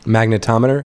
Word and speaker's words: magnetometer: like he refers to like magnometer, magnometer whatever you call it, like uh, um, magnetometer. – Yeah, yeah, magnetometer. Yeah, I missed a syllable in there,